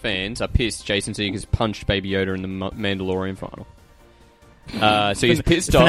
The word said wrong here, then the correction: finale: It's pronounced finale. – Yeah, thanks. Finale.